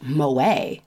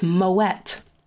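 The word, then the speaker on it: Moët: Um, speaking of that, did you know that the fancy champagne is not pronounced Moet like you would think because it's fancy? It's Moet.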